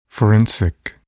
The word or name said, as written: forensic